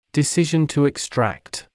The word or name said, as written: decision to extract